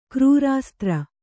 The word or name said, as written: ಕ್ರೂರಾಸ್ತ್ರ